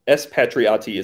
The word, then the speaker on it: Espatriati: It's Espatriati is how you pronounce it.